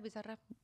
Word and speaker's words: Bizarrap: Yeah, Bizarrap.